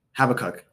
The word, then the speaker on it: Habakkuk: Habakkuk.